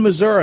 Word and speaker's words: Missouri: In Missouri, and I even said it right.